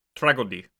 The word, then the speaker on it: Tragödie: And today we are continuing German Expressionism with a discussion of Algol, Tragödie der Macht, or Algol, Tragedy of Power. – Tragödie. There's no soft G's in German.